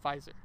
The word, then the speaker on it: Pfizer: Oh yeah, it is called Pfizer.